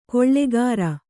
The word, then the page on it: ಗಳೆಕಾರ: Kannada